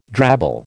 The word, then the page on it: drabble: American English